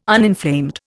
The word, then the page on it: uninflamed: Indian